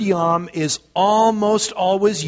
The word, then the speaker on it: yom: Because the Hebrew word that is used here for day is the simple word yom, and it's spelled just the way it sounds as we transliterate it into English. It's Y-O-M.